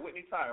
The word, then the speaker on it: Whitney Tai: I'm saying it right. Whitney Ty, right? – Yeah, that's right.